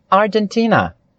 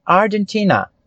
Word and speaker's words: Argentina: Argentina. A sílaba mais forte, ela é a ti. Argentina.